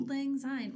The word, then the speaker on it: Auld Lang Syne: Old, Auld Lang Syne. Well, it's spelled A U L D, so Auld, Lang Syne.